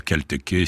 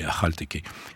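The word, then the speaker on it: Akhal-Teke: Et d'ailleurs, la Khalteke, oui, qu'on peut dire aussi à Khalteke si on est moins snob que moi, mais enfin, moi je mets un point d'honneur à avoir la prononciation indigène, donc c'est Khalteke.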